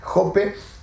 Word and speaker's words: Jope: Eh, Jope eh, se escribe e, J-O-P-E, Jope.